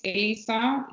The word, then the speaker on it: Elisa: And you can find me through my name is Elisa. You spell it E-L-I-S-A underscore last name Morales, M-O-R-A-L-E-S underscore PhD.